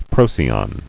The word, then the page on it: Procyon: Pro·cy·on